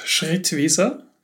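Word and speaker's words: Schrittwieser: I guess my last name, it's pronounced Schrittwiese. I'm from Austria, so you can tell it's German.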